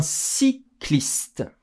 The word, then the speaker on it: cycliste: Un cycliste. Y se prononce comme I.